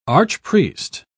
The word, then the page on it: archpriest: British English